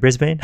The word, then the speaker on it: Brisbane: Did I say it right? Brisbane? – You did so right.